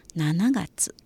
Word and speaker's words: 七月: 七月以前在很月的时候，很多的时候都是读作西七嘎子，它原本的发音也是西七嘎子，但是因为很容易被错听成一月份的一七嘎子，为了防止被听错，所以七月在很多的时候都被读成ナナガツ。随着时代的变迁，现在又被读成了西七嘎子了。